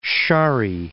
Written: Shari

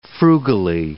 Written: frugally

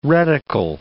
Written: reticle

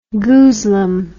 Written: goozlum

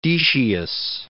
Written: Decius

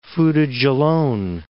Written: Fouta Djallon

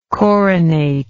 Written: coronate

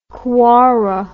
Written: Kwara

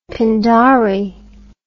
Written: pindari

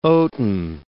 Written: oaten

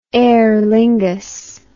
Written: Aer Lingus

